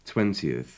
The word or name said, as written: twentieth